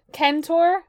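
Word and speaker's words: Kentaur (κένταυρος): And this is the Kentaur? Centaur would be pronounced Kentaur in Greek.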